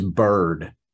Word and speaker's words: Byrd: a lefty quarterback named his nickname is Bird, except it's spelled B-Y-R-D because he looked like a baby bird when he was a baby.